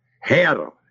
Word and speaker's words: Herr: Herr, H-E-R-R. That's the same meaning as the word Lord.